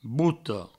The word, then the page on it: bota: bouto